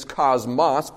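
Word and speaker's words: cosmos (κόσμος): The Greek pronunciation is cosmos, but the world, cosmos.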